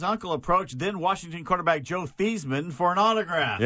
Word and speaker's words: Theismann: And by the way, his name was Theismann, and they changed it at Notre Dame to Theismann, rhymes with Heisman.